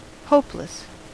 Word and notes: hopeless: American English

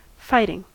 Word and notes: fighting: American English

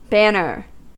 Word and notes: banner: American English

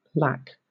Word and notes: lac: British English

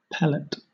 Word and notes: pellet: British English

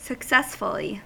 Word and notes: successfully: American English

